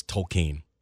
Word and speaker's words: Tolkien: It's Tolkien. I've read all the things and stuff. I'm like, it's Tolkien. There's a biography coming out about Tolkien, and that's how you pronounce the name, the proper way.